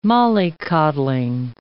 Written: mollycoddling

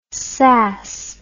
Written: sas

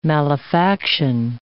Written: malefaction

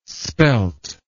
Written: spelt

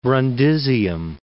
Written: Brundisium

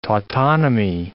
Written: tautonymy

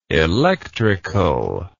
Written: electricalness

